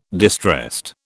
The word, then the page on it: distressed: British English